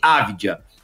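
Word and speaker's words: Avdija: Então é Deni Avidia a pronúncia correta do, do jogador israelense, a gente vai falar mais sobre ele.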